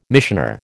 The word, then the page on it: missioner: American English